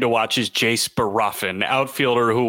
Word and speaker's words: Bohrofen: It's not Borofin. I think it's Barofin. – Barofin. B-O-H-R-O-F-E-N for those who are not following along.